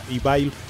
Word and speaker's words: Evile: Sí, bueno, y ya nada más una pequeña corrección, para el Radio Escucha también, porque ya lo habíamos programado anteriormente, el nombre de la banda se pronuncia Evil.